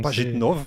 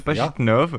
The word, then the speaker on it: Pajitnov: Alors, Pajitnov, j'arrive pas à le prononcer. – Pajitnov, Pajitnov, yeah.